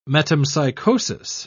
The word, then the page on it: metempsychosis: British English